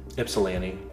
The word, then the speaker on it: Ypsilanti: But after those two got sick, they postponed their Flint show, and I can't ever say it right. The ones are to the Y. – Ypsilanti. – Yeah. Ypsilanti. – I don't know if it's Ypsilanti or Ypsilanti, but it's close enough.